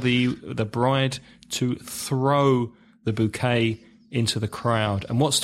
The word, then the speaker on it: bouquet: the bride usually has a bouquet of flowers. Bouquet, B-O-U-Q-U-E-T. Are you going to tell us how you pronounce that in French? – Bouquet. – Bouquet. Bouquet. Sounds much better in French, doesn't it?